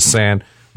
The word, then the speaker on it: Seine: Let's talk about Paris, because you mentioned Paris and the Impressions of the Seine River, S-E-I-N-E, Seine in French, because you say some of these words and people go, what do you say? He only said one syllable, S-E-I-N.